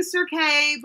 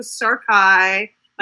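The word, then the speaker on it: Serkay: Yeah, we were saying Serkai, but remember, everybody, in America, that's how you would say it. And then it's a Serkai, like Versailles.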